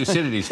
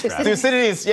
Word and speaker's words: Thucydides: I cannot pronounce the name of the Greek philosopher that the Chinese president mentioned. Do you know what? Thucydides. – Thucydides. Yes.